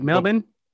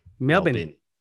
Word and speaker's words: Melbourne: You say it Melbourne? – Yeah, we say Melbourne. – Melbourne.